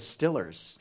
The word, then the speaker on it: Steelers: What's with as we say in Pittsburgh, the Stillers? That's how they say it.